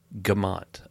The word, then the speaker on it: Gamont: Like G'MONT. Gamont. – I've been saying it wrong for 12 years? – Well, not 12, but yeah. – On podcasts and everything? Wow. Gamont. Okay.